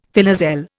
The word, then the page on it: filoselle: Indian